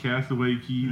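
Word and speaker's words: Castaway Cay: Oh my gosh, Castaway Key, though. Don't call it K, even though it's spelled C-A-Y, it's key.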